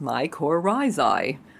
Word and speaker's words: mycorrhizae: We were chatting about planting protocols and whether to use mycorrhizae, pronounced mycorrhizae. Okay, my phonetics may sound silly, but for sure mycorrhizae is the root of plant health.